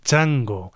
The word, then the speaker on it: Tjango: donde escuchábamos la música de este grupo, Chango. Se escribe T-J-A-N-G-O.